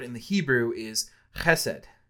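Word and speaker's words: chesed (חסד): Some of you may have heard about it before, but the word is chesed. And I am going to do the guttural with it because that's how you have to pronounce it.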